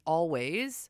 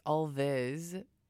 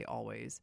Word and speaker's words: Alvvays: Then we get into this band, Always. Or if you pronounce it phonetically, it's Alvis, A-L-V-V-A-Y-S, but they say Always.